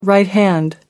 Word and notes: right-hand: American English

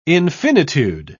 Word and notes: infinitude: British English

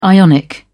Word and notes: ionic: British English

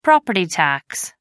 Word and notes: property tax: American English

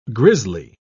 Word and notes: grizzly: British English

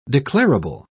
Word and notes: declarable: British English

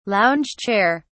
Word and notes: lounge chair: British English

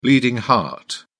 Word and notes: bleeding heart: British English

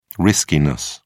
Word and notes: riskiness: British English

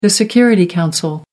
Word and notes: security council: American English

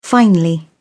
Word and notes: finely: British English

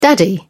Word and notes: daddy: English; British English

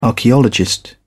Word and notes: archaeologist: British English

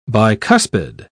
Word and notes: bicuspid: British English

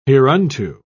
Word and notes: hereunto: British English

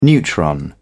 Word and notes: neutron: British English

